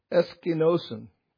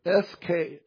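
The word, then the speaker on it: eskenosen (ἐσκήνωσεν): It's pronounced eskenosen, or eskenosen, eskenosen.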